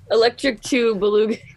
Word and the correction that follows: Boogaloo: Electric Boogaloo is what you're trying to say.